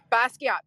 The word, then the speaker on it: Basquiat: Am I pronouncing his name? – Yes, Basquiat. Okay. – Basquiat, thank you.